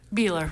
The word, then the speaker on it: Buehler: Buehler.